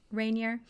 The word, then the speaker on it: Ranier: Rainier. Is that how you pronounce that? R-A-N-I-E-R. Yeah. Rainier.